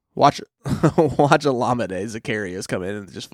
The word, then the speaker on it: Olamide: I'm pretty sure we're all set that Olamide is the correct way of pronouncing the first name.